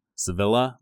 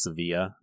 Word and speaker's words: Sevilla: It's spelled like Sevilla, but it's Sevilla is how they pronounce it.